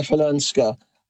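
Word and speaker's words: Chwalińska: Oh, go on, try pronouncing her name. – Svalinska. – You're close. Okay. There've been a couple of variations on the pronunciation, but I'm going with Maja Falunska.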